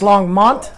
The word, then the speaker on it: Longmont: What am I saying? – Longmont sounded like. – And it's Longmont? – Yes. – That's that twang. – It's that Oklahoma draw, man.